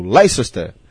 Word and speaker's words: Leicester: O nome do time se escreve Leicester. – Mas é Leicester. – Mas é como se fosse duas sílabas só. Você fala como se fosse Leicester. – Então Leicester venceu por 2-0 aqui pro Sunderland. – Como se o Leicester falasse bem rápido, Leicester. É como se fosse transformasse uma sílaba só.